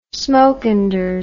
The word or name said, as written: Smokenders